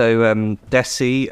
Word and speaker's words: Desi: Yeah, so Desi, which rhymes with Lionel Messi, so you say it like that, is a word that means, it's a Sanskrit word, and it means the homeland.